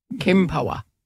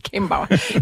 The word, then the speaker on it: Kempower: Eller, du sagde, den skulle nok udtales. Kempower. – Ja, Kempower.